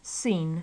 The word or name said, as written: seen